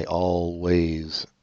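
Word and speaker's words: Alvvays: Okay, let's listen to Archie Marry Me by Always. Apparently that's how they say it's pronounced.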